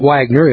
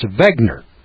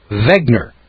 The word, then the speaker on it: Wegner: And my name is not Wagner, it's Wegner. Wegner. With a V. Wegner. But it's spelled with a W.